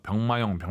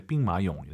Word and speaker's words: 兵马俑: 병 마 용 병마 용하 지마 시고 이제 빙 마 용 예 쁘 게 발음하 시기바 랍 니다